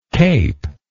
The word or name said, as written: caped